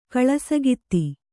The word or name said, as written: ಕಳಸಗಿತ್ತಿ